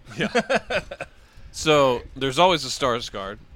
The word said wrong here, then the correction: Skarsgård: You said it right. Skarsgård. – Yeah, correct.